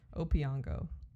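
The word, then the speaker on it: Opeongo: It's hard E. Opiongo. – Opiongo. – I thought it was like maybe Opengo, but no. Sounds Opiongo, like guys.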